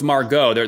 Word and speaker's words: Margot: Margot. – Oh, it's Margot. The T's silent. – Yeah, silent T.